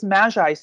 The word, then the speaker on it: Maggi: I think it's French onion soup and you use Maggi seasoning. Is that how you say it? Yeah.